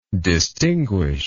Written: distinguishedly

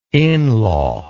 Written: inlaw

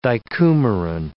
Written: dicoumarin

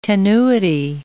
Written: tenuity

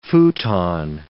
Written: futon

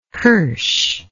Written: qirsh

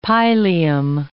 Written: pileum

